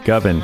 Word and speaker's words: Govan: That's right, folks, Govan. I'm on a campaign to get people to pronounce his name correctly.